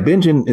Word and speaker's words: Bingen: Is it in Bingen, Washington? Is that how it's pronounced? – Bingen, Washington. Yeah, Bingen is not a huge town.